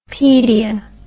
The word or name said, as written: pedia